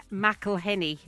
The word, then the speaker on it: McElhenney: This is when Rob McElhenney, I don't know how you say his name. – Rob McElhenney? – McElhenney.